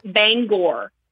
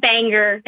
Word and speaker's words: Bangor: I don't know if I'm the first Mainer you've ever talked to, but for everyone who's listening, it is the city is pronounced Bangor. Bangor. Stephen King lives in Bangor, not Banger, not Banger. – Bangor. – Not Banjor, Bangor.